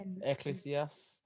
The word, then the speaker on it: Ecclesiastes: Ecclesiastes.